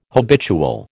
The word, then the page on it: habitual: American English